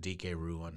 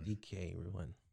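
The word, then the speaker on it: Dike Ruan: How you say his name, Dike? – I pronounce it DK Ruin. – DK Ruin. Okay.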